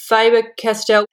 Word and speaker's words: Faber-Castell: The Faber-Castell, is that how you pronounce it? – Faber-Castell.